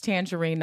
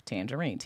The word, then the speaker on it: Tanjareen: If you know how to spell my name, it's Tangerine, not spelled like the fruit. It's TANJAREEN. I'm on all social media platforms, and my Patreon is obviously just Tangerine, TANJAREEN.